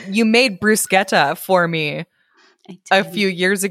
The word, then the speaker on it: bruschetta: It's pronounced bruschetta because it's like an Italian thing. It's not a French thing, but in French it would be bruschetta.